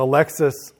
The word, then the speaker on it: Alexis: But Alexis, or Alexi, is spelled A-L-E-X-I-S. And they're very clear in the video, like, it's not Alexis, it's Alexi.